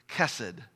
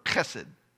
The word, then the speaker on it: chesed: In Hebrew, it's the word chesed, C-H-E-S-E-D. If you pronounce it like it's written in Hebrew, it's like you're clearing your throat. Chesed. And we'll just say chesed so that I don't spit on my family.